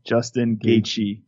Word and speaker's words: Justin Gaethje: Justin Gaethje.